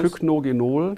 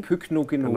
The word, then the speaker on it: Pycnogenol: Pycnogenol. – Pycnogenol, genau, ja.